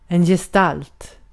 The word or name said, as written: form